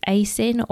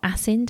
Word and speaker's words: ASIN: The next one I'm going to talk about is ASIN or ASIN, depending how you say it.